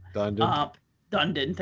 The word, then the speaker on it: Dundon: Dundon, thank you.